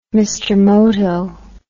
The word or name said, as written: Mr Moto